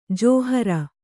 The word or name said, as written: ಜಾಹಿರು